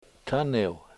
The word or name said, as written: taniw